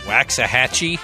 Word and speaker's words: Waxahachie: This is an alternative band called Waxahachie. – Nailed it. – Out in the Storm. As a chick singer, John, so watch out. – I lived there for a couple of years. – In Waxahachie?